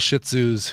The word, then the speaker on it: Shih Tzu: Yeah, we know a couple Shih Tzus who heavy on that first syllable.